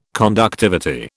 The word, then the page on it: conductivity: British English